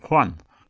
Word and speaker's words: Juan: For example, the Manx form of John is Juan, which is spelt J U A N. And if you're not familiar with Manx pronunciation and spelling, you might think it was the Spanish name Juan, but it's not. It's Juan.